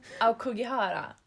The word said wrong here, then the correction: Aokigahara: Okej, vi kan inte japanska, men Aokigahara. – Just det, där satte du det.